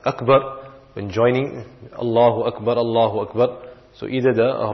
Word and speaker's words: Allahu Akbar: And then when pronouncing it, one should not make a mistake of lengthening the for example, Allahu, or you lengthen the lam, Allahu, or the word Akbar, you say Allahu Akbar. So these are the incorrect pronunciations.